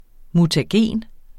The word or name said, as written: mutagen